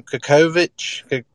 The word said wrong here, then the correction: Kokoskov: I think it's Kokoskov. – Kokoskov, okay. Yeah, that sounds more like it.